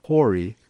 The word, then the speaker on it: Khoury: And I was asking Suhail, we would say Khoury, K-O-U-R-Y, but you don't really say the K so much. The way it's actually pronounced is more Khoury.